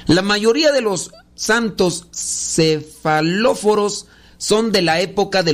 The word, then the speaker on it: cefalóforos: Los cefalóforos, los cefaloros, ándele, No, los cefalóforos los cefalóforos. ver, es una palabra griega que, no, no está difícil de pronunciar.